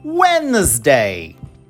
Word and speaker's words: Wednesday: Então, vamos repetir comigo quarta-feira em inglês: Wednesday. Então, embora a gente tenha dois, duas letras Ds ocorrendo nessa palavra, a gente apenas pronuncia a que vem para a palavra dia, day.